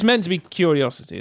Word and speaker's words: Qriocity: It's spelled Q-R-I-O-C-I-T-Y. – Everyone is going to pronounce it Curiosity.